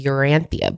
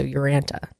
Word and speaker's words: Urantia: The founders of Celestial Seasonings, Mo Siegel and John Hay, were followers of this new age biblical text that's called the Urantia book. – Or Urantia. It's kind of pronounced a couple different ways. – I guess you take the I out. So Urantia.